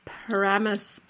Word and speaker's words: Paramus: So, again, you're not native Jersey, but why are we playing Paramus Park Mall?